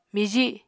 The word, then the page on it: miji: Apatani